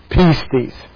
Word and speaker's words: pistis: The root word from which we get faith is from the noun, which is pronounced pistis, which is and belief.